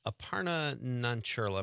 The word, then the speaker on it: Aparna Nancherla: Patton Oswalt, Punky Johnson, Sean Patton, and Aparna Nancherla.